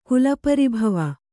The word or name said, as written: ಕುಲಪರಿಭವ